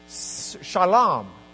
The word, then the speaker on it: Shalom: So it's interesting to read the Hebrew because you've got Melchizedek's name, and then immediately afterwards it says Melech, king, Shalom is the way it's actually pronounced, Salem, but it's got an SH sound to it.